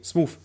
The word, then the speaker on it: smooth: Smo ja, ich habe Smuff gerade gesagt, deswegen Smooth.